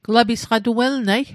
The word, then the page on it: welnat: Mi'gmaq